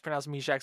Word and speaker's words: Mysak: The lick my sack tweet was basically, remember a couple, Montreal actually drafted Yan Meshack, pronounced Meshack, spelled M-Y-S-A-K.